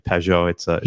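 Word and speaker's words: Pageau: Peugeot, is that how you say it? – Yeah, Peugeot.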